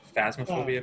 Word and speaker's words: Phasmophobia: It's called Plasmaphobia. It's PH. Phasmophobia, maybe? – Yeah, it means like fear of ghosts.